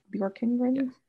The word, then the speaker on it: Bjorkengren: So Bjorkengren. – Like the singer and then Kengren, right? – Bjorkengren.